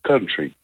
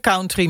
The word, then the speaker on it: country: Oké, dus dat spreek je uit als country. – Dus we zeggen het verkeerd als we zeggen country muziek.